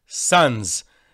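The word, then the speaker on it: Sanz: in Polish, the name of Tzanz is Novi Satz, New Tzanz. So it's S, Tzanz. And in Yiddish, it's pronounced Tzanz. So he said to him,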